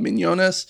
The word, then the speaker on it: Mignonnes: It's called Mignonnes in France. I doubt that's how you pronounce it correctly, but I think you got it.